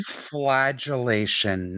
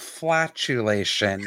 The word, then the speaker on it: flagellation: Flagellation, not flatulation.